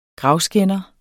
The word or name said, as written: gravskænder